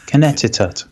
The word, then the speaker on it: Connecticut: Yes, he says, most commonly spoken with a silent second C. Sorry. – Huh? Connecticut.